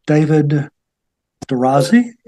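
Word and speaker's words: David D'Orazi: Today we have author David Durazzi, is that right? – That is correct. David Durazzi, you pronounce it well.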